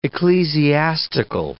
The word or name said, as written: ecclesiastical